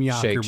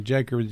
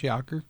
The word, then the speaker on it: Jaker: Some people call me Jaker just because they don't know no better and because it's kind of spelt that way, but it's pronounced Yawker.